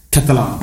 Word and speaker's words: Catalan: Hur uttalas ditt efternamn? – Alltså man kan ju säga på två olika sätt, Catalan eller Catalan. – Catalan. – Man får bestämma själv.